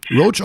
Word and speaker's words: Roach: Roach, also, ja, ja, wie, wie, um ehrlich zu sein, wie Cockroach. Also R-O-A-C-H.